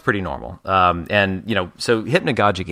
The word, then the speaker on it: hypnagogic: But it is hypnagogic and hypnagogia.